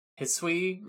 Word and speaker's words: Hisui: I like that it comes from a cool Pokemon, though I also appreciate that it only comes from Scyther in the Hisui region. Is that how you say it? – Yeah, in the past.